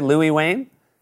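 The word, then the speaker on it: Louis Wain: You also have a show coming out on Amazon Prime called The Electrical Life of, am I saying this right, Louis Wayne? – Yes, you are saying it right.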